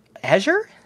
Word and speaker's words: Azure: Now, how do you pronounce it? – Azure. – Yeah, no, that's it.